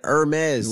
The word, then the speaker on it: Hermes: Not herpes, man. Hermes.